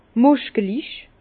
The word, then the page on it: muskelich: Bas-Rhin; Herrlisheim